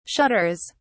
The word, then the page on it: shudders: British English